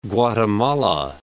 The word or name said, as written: Guatemala